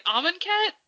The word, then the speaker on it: Amonkhet: I've been pronouncing it Amonkhet. – Amonkhet? – Like almond cat, but not quite.